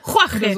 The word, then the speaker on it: Jorge: Jorge.